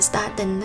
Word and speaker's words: statin: Một khẩu phần quả hạch Brazil có thể làm giảm cholesterol nhanh hơn thuốc statin, hay là trinh hay đọc là statin đó mọi người, và giữ mức ổn định thậm chí đến cả tháng trời chỉ sau một lần ăn.